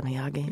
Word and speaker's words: Miyagi: Is that how you pronounce it? – Mr. Miyagi. – Mr. Miyagi. – Karate Kid.